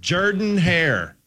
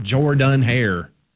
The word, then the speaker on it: Jordan-Hare: Jordan-Hare. That's how you say the stadium. It's not Jordan-Hare.